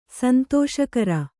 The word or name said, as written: ಸಂತೋಷಕರ